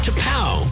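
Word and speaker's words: T'Pau: T-apostrophe-P-A-U, pronounced to pow.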